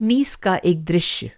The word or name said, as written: नीस